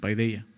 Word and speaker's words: paideia: Paideia, ¿no? Paideia, más bien, se, se pronuncia así en griego.